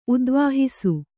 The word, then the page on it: ಉದ್ವಾಹಿಸು: udvāhisu